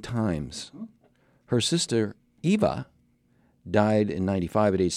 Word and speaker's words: Eva: Now, I always thought Zsa Zsa Gabor was in Green Acres, but it was her sister, Eva Gabor. I think it's EVA, Eva. You always pronounce it Eva? Well, you've always heard it wrong. – Okay. – I believe it's EVA.